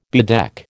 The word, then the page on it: bedeck: American English